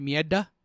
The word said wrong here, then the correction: Merida: Nope. No, Merida. Not Mieda.